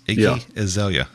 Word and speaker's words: Iggy Azalea: And then on top of that too, there a few years ago, maybe if you remember, they booked Iggy Azalea. – Iggy Azalea? – Yes, Iggy Azalea.